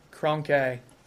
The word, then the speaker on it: Kroenke: Kroenke.